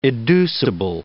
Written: educible

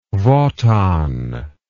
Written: wotan

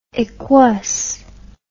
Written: equus

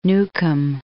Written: Newcomb

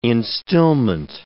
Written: instillment

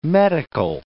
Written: metical

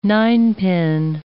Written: ninepin

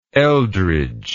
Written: Eldridge

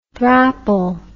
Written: thrapple

